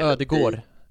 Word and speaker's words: Ødegaard: Du just sa det, ingen D. – Just det, Ödegård. Precis, Silent D Ödegård. I slutet.